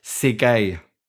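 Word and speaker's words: Tsegaye: Ja, ich hätte jetzt gesagt, weil ich habe sogar schon mal nachgeschaut, der heißt Ezra Segay. – Segay? – Ja, das T ist stumm.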